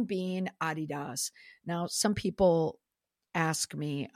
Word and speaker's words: Adidas: And also, the reason why I say Adidas is because that's how it's pronounced in the UK.